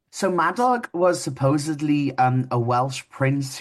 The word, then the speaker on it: Madog: So the Maddox Center, how do you pronounce that? Madog or Maddog? – You've got it. Yeah, Madog, the Maddox Center for Welsh Studies.